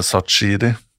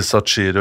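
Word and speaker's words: Shaqiri: 'Nei, du sier Sachiri og ikke sa Shakiri'. Ja, ok, så da er jeg, fordi jeg prøver å uttale det riktig, så er jeg en sånn type?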